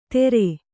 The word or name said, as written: ತೆರೆ